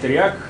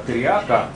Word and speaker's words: триак: Иногда триак произносится как триака, поскольку чудовище и все, что к нему прилагается, там было женского рода в этом греческом. Это, в принципе, два равноценных между собой термина, что триак, что триак.